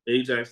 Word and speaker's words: Ajax: Is it Ajax? – Ajax, like the cleaning thing.